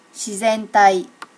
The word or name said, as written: shizentai